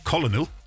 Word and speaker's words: colonel: Colonel, we say it correctly. Colonel. – Yeah, it's spelled that way. It's, you know, when you look at it, you think that's the way you say it, but colonel, yeah.